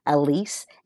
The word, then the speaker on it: Elise: And Elise is spelled E-L as in Larry, I S as in Sam, E, Elise.